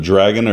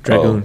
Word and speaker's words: Dragoon: Hey, the game you're playing, how do you pronounce it? The Legend of Dragon or Dragoon? – Dragoon. – Oh, I could have asked you. – Dragoon. – Yeah. Legend of Dragoon.